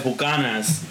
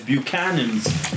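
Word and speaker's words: Buchanan's: So he's correcting the pronunciation of Buchanans because he's saying that other people call it Buchanas, but yeah, that's not the right pronunciation.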